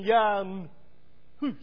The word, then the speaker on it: Jan Hus: In fact, the pronunciation of John Huss' name would have been Jan Hus, rhyming with the English translation. So we've got Goose.